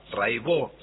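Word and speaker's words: Raybaut: Cata Raibó. Se escribe Raibaut, métanse, búsquenla, Rai con Y, Baut con B larga. Catalina Raibaut, Raibó se dice, y bueno, cotidiano son 10 canciones, hay un documentalito muy lindo.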